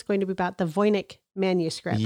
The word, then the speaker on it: Voynich: Okay, we're going to go with Voynich.